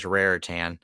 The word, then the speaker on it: Raritan: Yeah, there's an I before it, so it's Raritan.